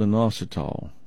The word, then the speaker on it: inositol: It's called inositol. Let me spell it for you. I-N-O-S-I-T-O-L. Inositol.